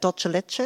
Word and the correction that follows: dulce de leche: Is that how you say it? – Dulce de leche. – Dulce de leche.